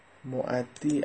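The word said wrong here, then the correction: مؤدا: عزیز من اصطلاح نیست، معدا درست تلفظ کن.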